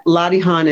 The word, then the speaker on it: Latihan: Yeah, Latihan is L A T I H A N, Latihan.